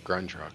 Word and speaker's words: Grunt Truck: I thought it was Grunt Truck, but... – I think it's just Grunt Truck.